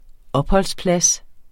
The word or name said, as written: opholdsplads